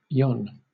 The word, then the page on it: yon: British English